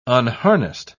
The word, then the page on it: unharnessed: British English